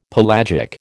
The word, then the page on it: pelagic: American English